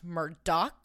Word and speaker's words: Murdaugh: Anyways, so before we get into this, the Murdaugh, which is how it is spelled, case is actually pronounced Murdaugh, that I learned.